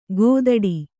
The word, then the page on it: ಗೋದಡಿ: gōdaḍ